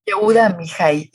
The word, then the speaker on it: Yehuda Amihai: Yehuda Amihai. – Yehuda Amihai.